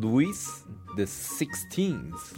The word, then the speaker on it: Louis the 16th: The King Louis the 16th.